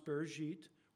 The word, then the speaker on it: Bereshit: From the book in Hebrew, it's pronounced Bereshit, which is Genesis chapter 3.